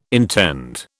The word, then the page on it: intend: British English